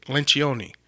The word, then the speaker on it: Lencioni: I think I'm saying that right. He said how to say it, Lencioni.